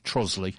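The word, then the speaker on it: Trottiscliffe: It is actually spelt Trotters Cliff, but pronounced Trosley.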